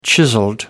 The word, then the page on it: chiseled: British English